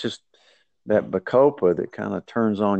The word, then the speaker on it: Bacopa: It's spelled Bacopa, just so our listeners can look it up if they need to. – Okay. BA. COPA, Bacopa.